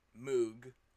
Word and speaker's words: Mogg: They're about a witch called Meg with two G's, M-E-G-G, and her cat, Moog, I believe is how you pronounce it, M-O-G-G.